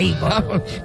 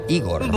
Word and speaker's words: Igor: No, se pronuncia a Igor. – Me dijeron que era Igor. – Bueno, pues se equivocaron.